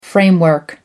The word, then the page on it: framework: American English